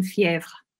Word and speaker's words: Fièvre: Do you want me to say? – Yeah, I do. – Fever. Julien Doré and Fièvre.